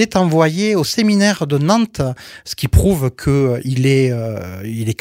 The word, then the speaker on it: Nantes: Alors en Aveyron, on prononce toutes les lettres, mais là, pour une fois, on prononce Nantes. Tu vois, il y a la subtilité. – Okay. – On prononce pas le E. Okay. Quand on prononce Nantes, teux, c'est Nantes en Bretagne.